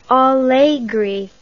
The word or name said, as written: Allegri